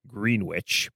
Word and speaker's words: Greenwich: Yes, that's how they pronounce it down there, Greenwich.